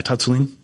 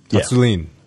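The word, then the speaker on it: Tsatsouline: Did I say that right? – Yeah, Tatsulin. – Tatsulin, yeah.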